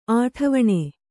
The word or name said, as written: ಅಠವಣೆ